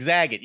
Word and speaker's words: Zagat: And Nora, it's like Zagat. You go read the Zagat. That's how you say it.